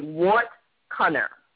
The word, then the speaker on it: wortcunner: It's called being a wart cunner. – Oh, okay. – And it's spelled wortcunner, and that's Old English.